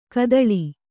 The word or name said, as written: ಘಾತಾಳಿ